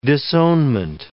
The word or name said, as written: disownment